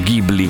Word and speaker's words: Ghibli: C'entra perché Miyazaki ha creato un grandissimo laboratorio che si chiama, come sapete, Studio Ghibli, o Ghibli come si pronuncia all'italiana.